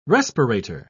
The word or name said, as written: respirator